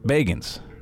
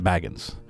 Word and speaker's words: Bagans: It's Zach Bagans, not Baggins.